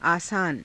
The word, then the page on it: aasaana: Hindi